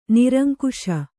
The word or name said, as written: ನಿರಂಕುಶೆ